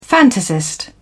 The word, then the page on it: fantasist: British English